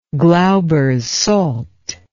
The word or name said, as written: Glauber's salt